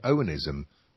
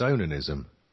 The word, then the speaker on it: onanism: Although I realised I was saying it wrong for so many years because I always used to say Onanism, but it's not, it's Onanism.